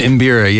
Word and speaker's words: mbira: And what's this electric mbira? Am I pronouncing that right? – It's an mbira, yeah.